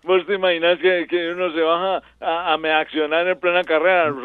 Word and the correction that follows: miccionar: Cochise, qué pena, pero no se dice meaccionar, sino miccionar.